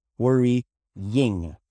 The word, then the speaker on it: worrying: And we pronounce it worry and then ying, like a yin yang, right? Yo, yo, yeah, yeah. Worry, ying. Two sounds, okay? Worry, yeah, sorry, three sounds. Worry, ying. Okay, three sounds.